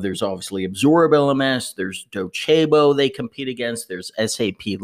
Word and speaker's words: Docebo: Some people pronounce it Docebo. The correct name is Docebo and similar competitors.